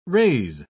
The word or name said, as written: raze